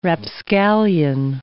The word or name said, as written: rapscallion